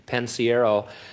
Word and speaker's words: Pensiero: I said, it's really simple, it's Pensiero. I said, just let's do it phonetically, and I had him write it down phonetically, Pensiero and stuff, and then he got up and introduced me as Gene Pensnario or something.